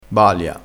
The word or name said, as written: balia